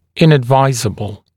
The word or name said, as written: inadvisable